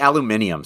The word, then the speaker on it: aluminium: You say aluminium, so shut up. – It's spelt differently. – Aluminium. – You know, it's spelt differently, right? Aluminium and aluminum, they're not spelt the same. That's why they're pronounced differently.